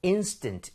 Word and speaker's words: instant: 원래 강세는 항상 모음에 가야 돼요. So, instant. 이렇게 앞쪽에 강세를 주고, 두 번째는 camera.